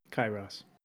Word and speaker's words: Kairos: Kairos. – Kairos. – Kairos. Okay, I'm writing it down. – Ross. – Ross, the largest of all friends. – It's spelled K-A-I-R-O-S, but it's pronounced K-I-R-O-S-S.